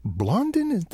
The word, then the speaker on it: Blondin: There was a guy named Blondin.